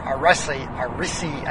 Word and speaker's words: Irssi: The uh, second program that I use quite a lot is uh, IRSSI or ARISI, as it's called. IRSSI is the way that it's spelled, and that is a command line based IRC program.